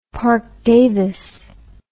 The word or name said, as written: Parke-Davis